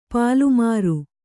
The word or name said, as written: ಪಲ್ಲುಮೊರೆ